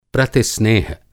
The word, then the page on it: प्रतिस्नेह: Hindi